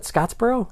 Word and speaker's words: Scottsboro: It is Scottsboro.